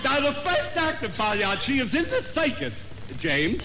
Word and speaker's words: Pagliacci: I'm going to do Pagliacci. – Pagliacci? – Well, Pagliacci, yes.